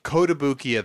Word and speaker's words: Kotobukiya: Oh, Kotobukiya, the Japanese statue maker.